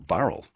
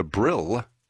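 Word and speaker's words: Burrill Lake: Just past Ulladulla is the Lions Park in Burrell Lake, or Burrell, depends which way you put the emphasis on the syllable. A beloved space that locals say needs a little bit of TLC. Now, the Brill Gathering,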